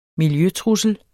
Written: miljøtrussel

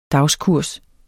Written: dagskurs

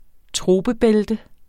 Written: troper

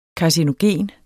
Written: karcinogen

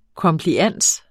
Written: komplians

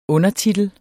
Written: undertitel

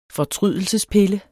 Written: fortrydelsespille

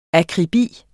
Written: akribi

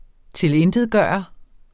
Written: TILINTETGJORTE

